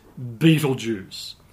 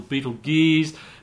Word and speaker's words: Betelgeuse: But not many people know it by that name because it has such a spectacular common name, and that is Betelgeuse. Yes, that's right, there is a star called Betelgeuse. Some people call it Betelgeuse or Betelgeuse, but they're all mispronunciations of the old Arabic name Ibtilyaza, meaning hand of the big man.